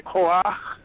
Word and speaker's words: Koach: The word for power in Hebrew is Koach. That's the word in Hebrew. It's two syllables, and it means that he will give you force.